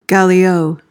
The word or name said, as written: gallio